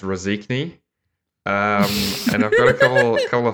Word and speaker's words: Rēzekne: So, Rēzekne, or as it's locally pronounced, Rēzekne.